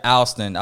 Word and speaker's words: Alston: Alston, yeah, I mean, it does have an L in it, but we just pronounce it Alston, you know? – Okay.